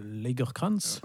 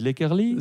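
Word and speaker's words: Lagercrantz: toujours écrit par David Lagerkrantz, ouais, si ça. je prononce bien son nom. Et non pas David Lakerly.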